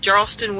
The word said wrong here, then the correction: Jarthus: Wastes? – Jarthus. – Jarthus Wastes.